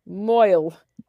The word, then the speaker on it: mohel: It is when the Mohel Mahu, how do you say Moil. It? – Moil. Okay, Viola. Holy shit.